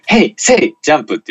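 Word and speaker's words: Hey! Say! JUMP: 弟の小学校時代の同級生に、平成ジャンプって、ちゃんと、ヘイ、セイ、ジャンプって読んだ方がいいのかな。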